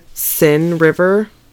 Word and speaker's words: Seine: Now, I love me a little bit of the fact that it's the Seine River. It's not how it's spelled, but it's how it's pronounced, so the Seine River.